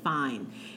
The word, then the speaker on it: Fein: F-E-I-N. – Melanie's F-E-I-N. Fine. – Fine. Oh, you got to say it like we say, no, Melanie Fine. She's Melanie Fine.